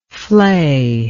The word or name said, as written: fleyedness